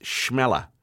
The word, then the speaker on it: Chmelar: And her surname is spelled Chmelar, and it is pronounced Schmeller.